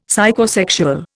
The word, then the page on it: Psychosexual: Indian